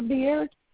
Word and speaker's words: Beard: Sonia Beard.